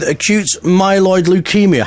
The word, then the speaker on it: acute myeloid leukemia: But your story is that you was diagnosed with acute myeloid leukemia. Have I said that right? – Yes.